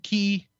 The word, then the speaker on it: Qi: I know that they accept the key chargers. – Right. – Or however they pronounce it, the QI chargers.